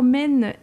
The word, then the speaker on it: Maine: Alors d'abord, Men, M-A-I-N-E, comme la rivière. Est-ce que j'ai bien prononcé déjà?